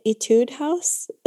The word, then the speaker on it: Etude House: So I, yeah, the tint, there's a tint that we use from, how do you say it? Etude House. It's like a Korean brand. Etude, E-T-U-D-E.